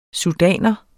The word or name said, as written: sudaner